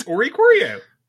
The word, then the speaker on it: Oricorio: Yes, it's Oricorio.